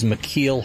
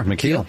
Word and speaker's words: McKeel: My extra special guest this week is McKeel Hagerty. Am I pronouncing that right? You are, McKeel.